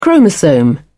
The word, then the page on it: chromosome: British English